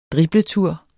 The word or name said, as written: dribletur